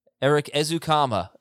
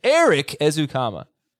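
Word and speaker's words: Erik Ezukanma: Eric Ezukama is how we pronounce it. Eric Ezukama.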